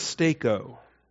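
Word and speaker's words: steko (στήκω): The verb is steko, S-T-E-K-O, the long E, long O, Eta and Omega, S-T-E-K-O.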